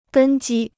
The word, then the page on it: 根基: gēn jī